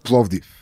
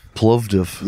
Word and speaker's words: Plovdiv: Plovdiv. – What's it called? – Plovdiv. – Plovdiv.